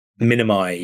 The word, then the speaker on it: Minimi: Is it Minimi? – Minimi, yeah.